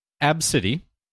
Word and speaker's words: ABCDE: Five-year-old ABCDE Redford pronounces her name Absidy.